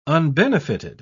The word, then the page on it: unbenefited: British English